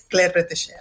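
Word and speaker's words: Claire Bretécher: Que nos dejó hace 6 añitos, en 2020, y menos mal que lo pronuncias tú bien, porque ya hemos intentado pronunciarlo en la redacción nosotros todo el día y somos incapaces de pronunciar bien lo de Claire Retesher, pero bueno, ya estamos cerrados para decirlo bien.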